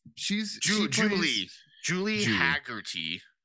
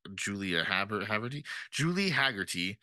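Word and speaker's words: Julie Hagerty: Julia Hattery. Is that the wife's name? Something like that, right? – Yeah, she's in stuff. – Julie Haggerty. God, I butchered that. Julie Haggerty, and she always plays like the spacey mom.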